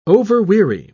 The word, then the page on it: overweary: British English